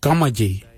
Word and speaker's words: gamadji: Jittery is spelt D-J-E-R-D-A-R-I, jittery, brown, jittery. And the last colour for green, we say gamaji, gamaji for green. Gamaji is spelled G-A-M-A-D-J-I, gamaji for green.